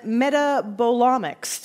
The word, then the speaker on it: metabolomics: And you're a data analyst in metabolomics.